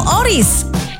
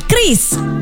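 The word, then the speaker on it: QRIS: Ada yang menyebut Qioris, Qiris, atau Oris. Kepala Grup Departemen Kebijakan Sistem Pembayaran DKSPBI, Fitria Irmi Triswati mengungkapkan, pengucapan yang benar adalah Qiris. Jadi, jangan salah ucap lagi ya.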